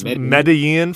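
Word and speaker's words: Medellín: He's a Spanish teacher, and he chastised me because I've been mispronouncing Medean. – I think it's Medellin. Is that correct? – Yes, it's Medellin for Spanish speakers, and then for people from Colombia, it's Medellin, which is even harder from a white guy from Chicago.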